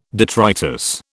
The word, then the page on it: detritus: British English